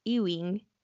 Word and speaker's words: Ewing: Ewing.